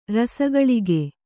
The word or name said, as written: ರಸಘಳಿಗೆ